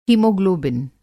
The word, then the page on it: हीमोग्लोबिन: Hindi